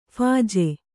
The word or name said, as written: ಫೀಜು